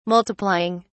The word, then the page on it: multiplying: British English